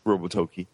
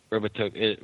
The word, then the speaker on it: Robotoki: Jumping ship from that, forgive me if I pronounce this wrong, but Robotiki? – The NES. – Robotoki. – Robotoki.